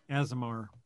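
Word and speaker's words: Aasimar: And in that game, I played a paladin that was an Azimir. – Azimar. – Is that how you say it? – Yeah.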